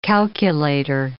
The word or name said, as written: calculator